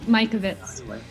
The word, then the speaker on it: Mikovits: Dr. Mikovic. I don't know if that said it right, but whatever. – Mike, Mikovits.